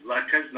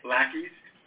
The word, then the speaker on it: latkes: The correct pronunciation is latkes, not lackeys.